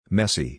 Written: messy

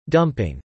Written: dumping

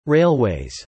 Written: railways

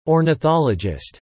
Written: ornithologist

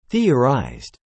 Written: theorized

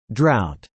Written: drought

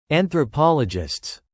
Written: anthropologists

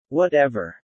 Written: Whatever

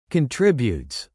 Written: declining

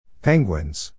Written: penguins

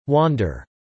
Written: wander

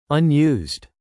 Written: unused

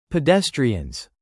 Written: pedestrians

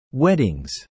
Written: weddings

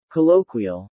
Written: colloquial

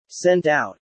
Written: sent out

